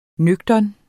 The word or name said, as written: nøgtern